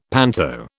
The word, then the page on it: panto: British English